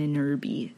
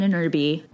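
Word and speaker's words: Ahnenerbe: An Inerby, probably ruining the pronunciation of that. It's A-H-N... E-N-E-R-B-E. So, phonetically.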